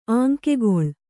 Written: ಆಂಕೆಗೊಳ್

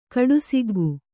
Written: ಕಡುಸಿಗ್ಗು

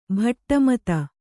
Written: ಭಾಟ್ಟಮತ

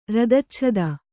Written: ರದಚ್ಛದ

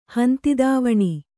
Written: ಹಂತಿದಾವಣಿ

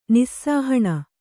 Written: ನಿಸ್ಸಾಹಣ

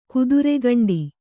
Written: ಕತ್ತರಿಗಂಡಿ

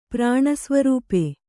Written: ಪ್ರಾಣಸ್ವರೂಪೆ

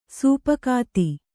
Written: ಸೊಪ್ಪುಗತ್ತಿ

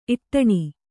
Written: ಇಟ್ಟಣೆ

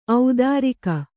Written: ಔದಾರಿಕ

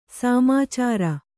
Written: ಸಾಮಾಚಾರ

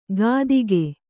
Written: ಕಾತಿಕೆ

